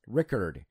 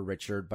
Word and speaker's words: Ricard: Or Richard, if you like that pronunciation. – Yeah, I think it's spelled R I C A R D, so Ricard, but or Richard, but yeah, Richard's easier to say.